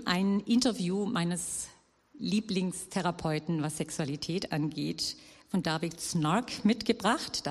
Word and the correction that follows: Schnarch: David Schnarch wird ausgesprochen.